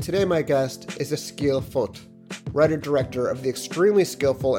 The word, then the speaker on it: Vogt: I know if you've seen the spelling of his name, V-O-G-T, you may think I'm hopelessly mispronouncing it, but he informed me that in his native Norwegian, the V is pronounced like an F.